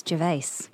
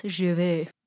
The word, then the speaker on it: Gervais: But then also it has Ricky Gervais on talking about, what it's like to be Ricky Gervais. – You know what? I think it's Gervais. – Oh, sorry. Thank you. Ricky Gervais. – That's your friend. That's how you say it in French, I guess. Gervais.